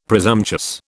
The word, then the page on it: presumptuous: British English